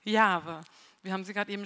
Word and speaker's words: Jahwe: Und deswegen ähm, haben wir diese Buchstaben J, H, W, H, die zwar hier auf der Bühne stehen, die auch auf den, auf den Flyern oder digitalen Medien waren, aber aussprechen wollen wir es Jahwe.